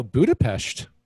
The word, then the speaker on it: Budapest: I you know, Budapest, which I just pronounced correctly, by the way, and the only reason I know how to pronounce it, like the Pest is because my, the lady that cuts my hair is actually Hungarian.